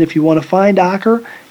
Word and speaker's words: Acre: which could be mispronounced Acre, but it's Acre.